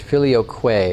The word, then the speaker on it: filioque: filioque is how it's actually pronounced, which means "and the Son."